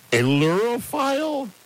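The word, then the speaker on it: ailurophile: Aerophile, A-I-L-U-R-O. So that's the prefix. – A-I-L. – Yeah, U R O, and then file.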